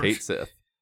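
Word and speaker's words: Cait Sith: going on a date with Cat Sheik or whatever. I don't remember. – Kate Sith. – I know it's not pronounced like it looks.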